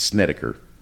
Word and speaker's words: Snedeker: And it talks about the Snedeker family. Snedeker family. It's hard to say. – Snickerdoodle family. – Yes, the Snickerdoodle. No, it's Snedeker. – It's Snedeker. – It's Snedeker.